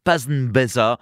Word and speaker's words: Pasnbesa: Ça s'écrit P-A-S-N-B-E-S-A, Pasnbeza.